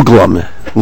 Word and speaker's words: Uglum: Uglum. Long U, sir.